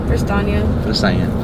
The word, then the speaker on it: Prestaina: Prestaina.